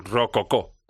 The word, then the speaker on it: Rococó: Rococó, con ahí el acento en la última.